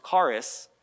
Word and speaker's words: charis: And she goes, well, I know in the Greek it's pronounced charis, and I know that it means grace.